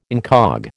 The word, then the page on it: incog: American English